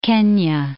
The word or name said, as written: Kenya